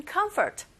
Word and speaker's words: comfort: Comfort는 첫 번째 음절에 강세가 있고 F 발음하실 때 comfort 하시면 안 되죠. Comfort 긁어주세요. 윗니로 아래에서 살짝만 닿으면서 이렇게 긁어주시면 됩니다.